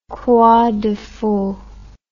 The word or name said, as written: croix de feu